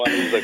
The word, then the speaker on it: ANISA: Presidente di ANISA, l'Associazione Nazionale Imprese e Servizi Autostradali, e naturalmente nel momento in cui pronuncio il nome dell'associazione mi viene il dubbio su dove porre l'accento. L'ho detto correttamente, buongiorno. – Va benissimo, ANISA comunque, grazie a lei, ANISA.